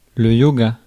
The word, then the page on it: yoga: France (Paris); [lə jo.ɡa]